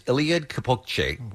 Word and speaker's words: Eliud Kipchoge: His name is Eliud Kipchoge, which is, I'm sure, pronounced wrong. – You nailed it.